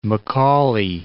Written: Macaulay

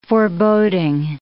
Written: foreboding

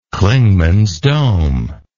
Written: Clingmans Dome

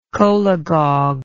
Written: cholagogue

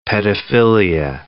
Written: pedophilia

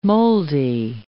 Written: moldy

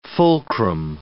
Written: fulcrum

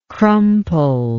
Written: crumpled

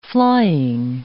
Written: flying

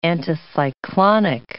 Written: anticyclonic

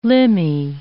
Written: limby

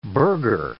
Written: burgher

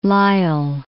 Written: Lyell

